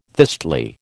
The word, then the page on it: thistly: American English